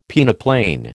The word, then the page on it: peneplain: American English